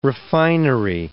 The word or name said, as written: refinery